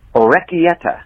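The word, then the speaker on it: orecchiette: Just responding to this week's episode and this week's edition of Encyclopedia Googlica, the pasta that Josh was referring to is pronounced orecchietta. Orecchietta. – Orecchietta. – No. Anyway. – Everybody's giving different pronunciations.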